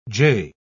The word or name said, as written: jay